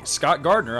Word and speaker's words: Scott Gardner: And hey, you can friend me, Scott Gardner, on Facebook too. My name is spelled S-C-O-T-T-G-A-R-D-N-E-R.